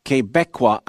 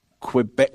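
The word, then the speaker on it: Québécois: Oh, and Quebecois is pronounced Quebecois and not Quebec.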